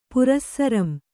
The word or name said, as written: ಪುರಸ್ಸರಂ